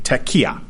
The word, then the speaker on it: tekiah: The first one here is the tekiah, or I guess it would be tekiah, the emphasis on the second syllable.